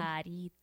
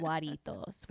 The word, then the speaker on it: Jarritos: So Jarritos. – Jarritos. – I'll never say it wrong again. It's not guaritos.